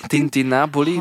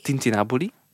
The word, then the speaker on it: tintinnabuli: Tintinabuli, dus oh. ja, inderdaad. – Tintina, Tintinabuli? – Tintinabuli. – Ja. waar ligt de klemtoon? De tintinabuli? – Gewoon snel uitspreken. tintinabuli, voilà.